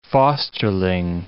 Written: fosterling

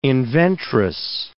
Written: inventress

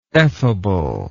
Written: effable

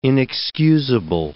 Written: inexcusable

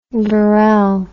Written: verel

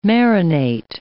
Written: marinate

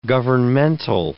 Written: governmental

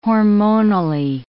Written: hormonally

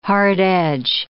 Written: hard-edge

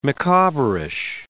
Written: Micawberish

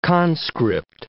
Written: conscript

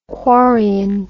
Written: quarrion

